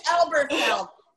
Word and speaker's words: Elberfield: And it's Elberfield. That's why I go by. – I'm sorry. – Because no one can pronounce it, no one can spell it.